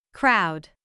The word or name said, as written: crowd